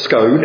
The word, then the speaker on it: Scone: Scone is spelled S-C-O-N-E. Scone is near Perth. And it's not a scone and it's not a scone, it's Scone. And that is historically where the kings of Scotland were crowned.